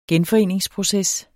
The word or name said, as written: genforeningsproces